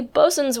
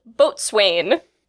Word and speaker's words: boatswain: For those of you who, like me, had read the term long before you ever heard it pronounced, it is pronounced bosun even when it's spelled boatswain.